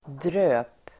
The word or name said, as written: dröp